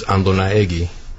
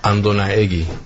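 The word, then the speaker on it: Andonaegui: There's two ways to pronounce it. The proper way in Spanish is Andonaegi. – Do that again? – Andonaegi.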